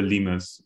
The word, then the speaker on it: Limes: Well, it's cooler when you give it the German pronunciation, which is the Limes, which is like an ancient wall they used to defend against the Romans.